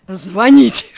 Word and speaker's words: звонить: Вот это все, мы, мы 8 тысяч раз это обсудили, что правильно говорить звонить.